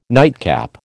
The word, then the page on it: nightcap: American English